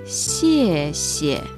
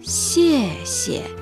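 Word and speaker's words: xie xie (谢谢): El punto difícil de xie xie está en su pronunciación. Se dice xie xie. Xie xie. No es xie xie. Al decirla, hay que mantener la lengua plana y las comisuras de la boca se estiran hacia los dos lados como una sonrisa. Así se puede pronunciar bien esta palabra.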